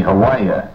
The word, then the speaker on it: Hawaii: When I say Hawaii, I think, should it be Hawaii? How do you say it over there? – You say Hawaii.